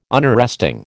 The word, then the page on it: Unarresting: American English